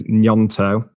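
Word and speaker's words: Gnonto: The correct pronunciation there is Nyonto. My Italian roots tell me.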